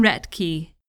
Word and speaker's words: Roethke: And we'll wrap up the show with a little trail wisdom from Roethke.